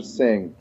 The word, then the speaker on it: Singh: It's Raj Singh. – Singh, like, okay, got it. – Imagine there's no H.